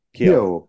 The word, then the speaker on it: Keough: Because I don't think I could, as much as I liked Riley Keough, or Keough, however you say her name, Alice Lane. – Keough.